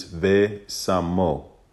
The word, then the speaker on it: ve samo: is ve samo. That's how you say it in Ivrit, which is a vav, a sin, a mem, and another vav.